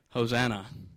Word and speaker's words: Hosanna: English translators didn't have a word that they could actually use to translate the Greek into English, so what they did is they made an English word that's spelled in such a way that it sounded just like the Greek word, Hosanna. So this morning, I am speaking Greek correctly when I say Hosanna.